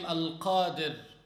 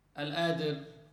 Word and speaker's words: Al-Qadir: For instance, Allah's name Al-Qadir. Don't say, as they say in slang, Al-Adir.